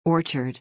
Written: orchard

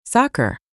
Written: Soccer

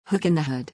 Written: hook in the hood

